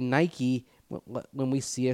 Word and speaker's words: Nike: Nike was a Greek goddess or god? – I believe goddess. – I think so, too. So there you go. – Yes. Different etymology there. But still, that's a good thought. – Yeah. It's the only one, I think, that doesn't like need that. A Y to be pronounced, to pronounce the E.